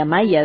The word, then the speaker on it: Amaya: y hoy de Amaya deben decir en El Salvador seguramente, que pronuncian la Y diferente a los uruguayos, y se llama Servicio de Día y de Noche.